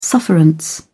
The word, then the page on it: sufferance: British English